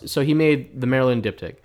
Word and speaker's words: diptych: Diptych.